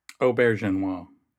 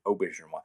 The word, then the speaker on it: Auberjonois: Auberjonois. – Yes, Auberjonois.